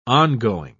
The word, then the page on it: on-going: British English